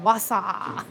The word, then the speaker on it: Wausau: I was like, Wausau. – Wausau.